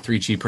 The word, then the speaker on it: 3Chi: 3Chi pronounced 3CHI, industry leader in hemp-derived cannabis products.